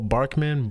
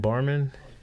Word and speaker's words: Barman: It's Paul Barman. Am I saying it right? Paul Barkman? Barman? Okay, Paul Barman.